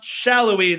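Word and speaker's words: Sallói: Sporting Kansas City, by the way, everybody out there, It's pronounced Shallowy. There's supposed to be a little accent on the top of the I, but I don't know how to do it, so it's fine.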